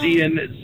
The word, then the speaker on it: Iain: Hold on, but your name is spelled I-A-I-N, and I thought I thought it was Ian. – Oh, it's Ian. It's Gaelic.